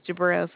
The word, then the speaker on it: Jabrail: My name is Jabril, sir. – How do you spell that? – J-A-B-R-A-I-L.